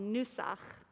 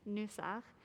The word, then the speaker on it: nusach: It's called nusach. That's hard with my mask on, but that's spelled with an N, like N for Nancy, nusach.